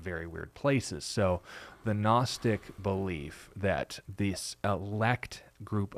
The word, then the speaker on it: Gnostic: Now, Gnostic, for those of you who speak English, think of the term like gnome. It starts with a G, but the G is silent.